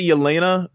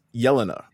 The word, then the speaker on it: Yelena: I'm talking about we say Yelena, but it's Yelena.